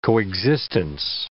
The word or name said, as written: coexistence